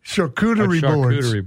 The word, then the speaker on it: charcuterie: You might have to help me pronounce it. Chakurt. It's some boards. – Charcuterie board, yeah.